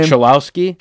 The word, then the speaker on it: Cholowski: Cholowski.